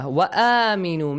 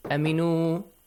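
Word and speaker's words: آمنوا: Make sure it has a long sound. Let's talk about the tajweed rules here, okay? وَآمِنُوا. Both long sounds, okay? Long vowel sounds. آمِنُوا. وَآمِنُوا. Do not say aminu. Wa aminu. Believe.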